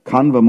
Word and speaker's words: Kanva: Is that Brahmin, what was his name? Kandava? Kanva Muni. Kanva, like Pagala Kanva? Kanva, of course. We're not interested in how Bhavananda pronounces it, but Kanva, yeah, like that Kanva, the famous gardener.